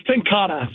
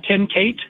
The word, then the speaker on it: Ten Kate: It's Tenkata. So it's spelled like Ten Kate. – Yeah. – But it's, yeah, Tenkata. – I'm learning too, Kevin. I was learning too. I called it Tenkate, you know, and then I was at the trade show this past week in New Orleans, and I learned a lot about the product.